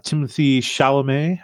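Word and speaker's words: Timothée Chalamet: Like, so the new movie came out, 2023's, Wonka with Timothy Chalamet.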